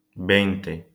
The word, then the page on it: veinte: Spanish